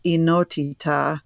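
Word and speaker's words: enotita (ενότητα): And then I just, on a whim, looked up unity in the Greek language, and it's enotita. Not that I speak Greek, but thank God for Google Translate, so it pronounces it for me. It comes from one, the numeral one in Greek is enas, ENAS with an accent over the E, and oneness is enotita.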